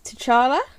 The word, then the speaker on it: T'Challa: It was the honour of his career to bring King, I'm going to butcher this, T'Challa? – That's correct.